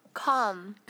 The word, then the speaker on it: calm: calm calm. 근데 여기서 L 발음 거의 안 나죠? – 네. – L이 무음이군요. – K-A-H-M처럼 calm.